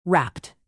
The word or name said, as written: rapped